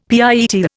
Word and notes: pietà: Indian

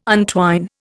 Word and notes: untwine: Indian

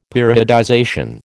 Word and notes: periodization: American English

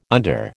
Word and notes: under: American English

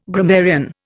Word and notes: grammarian: Indian

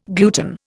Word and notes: gluten: Indian

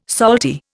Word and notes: salty: Indian

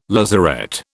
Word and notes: lazaret: British English

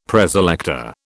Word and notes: Preselector: British English

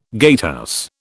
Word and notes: gatehouse: British English

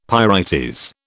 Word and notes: pyrites: British English

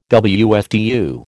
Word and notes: WFTU: American English

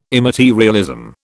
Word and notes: immaterialism: British English

